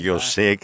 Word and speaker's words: José: Y estaban horrorizados, me dice Andrew Whitman, que me llama Jose, que es la forma inglesa de pronunciar José, de ver cómo estaba en todos los kioscos de prensa la pornografía llenando el país.